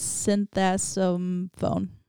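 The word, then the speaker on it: Sinthasomphone: His last name's really hard to pronounce. It's Synthasomphone.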